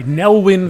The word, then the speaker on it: Nelwyn: The story began with an aspiring magician from a Newland village, I think I said that right, Nelwyn village,